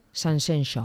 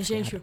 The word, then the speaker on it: Sanxenxo: A ti sonache mellor Sanxenxo. – Sí. Sí. – Pois é máis difícil de pronunciar. Sanxenxo.